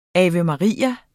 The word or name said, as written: ave